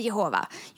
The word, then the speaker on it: Jehova: Så när det står På massa ställen i Bibeln så står det att Gud är JHVH och man kan uttala det Jahwe eller Jehova.